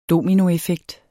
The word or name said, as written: DOMINOEFFEKT